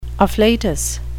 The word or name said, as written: afflatus